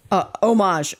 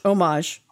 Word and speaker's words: homage: Homage. Omage. – Homage. – You don't pronounce the H. It's homage. Omage.